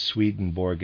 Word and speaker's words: Swedenborg: Readers aside, I realize Swedenborg is properly pronounced something like Swedenborg, but I'm going to pronounce it in the regular way, Swedenborg.